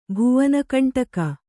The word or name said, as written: ಭುವನಕಂಟಕ